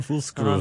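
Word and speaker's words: Fußgruß: If you see the weird looking B thing, it's pronounced as double S. There you go. – Fussgruß.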